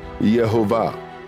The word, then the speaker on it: Jehovah: So, how is God's name pronounced? According to Strong's, Jehovah.